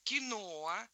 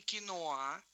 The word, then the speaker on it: киноа: Во-вторых, киноа, а не киноа. Да, как вот произносят многие, кино, ну, здесь хороший ориентир слова кино. Кино, кино, ну, добавили букву, ударение все равно сохранили на том же месте.